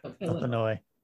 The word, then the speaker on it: Illinois: So I feel like Illinois is it Illinois or Illinois? – Silent S. – Okay. Illinois. I feel like it's a lot like Massachusetts.